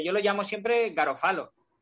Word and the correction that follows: Garofalo: Yo creo que es Garófalo. – Es como Garófalo. – Sí, yo creo que es Garófalo, que es como con el acentito, ¿sabes?